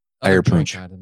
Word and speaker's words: Eierpunsch: Eierpunsch.